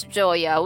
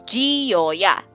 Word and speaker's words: Gioia: Basta ang laging, like, I will accept two pronunciations and two pronunciations only. The one is Joya, which how I would actually pronounce it. Like, how would an Italian pronounce it? The other is Gioia. Like, my emphasis kada syllable.